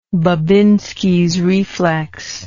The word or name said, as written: Babinski's reflex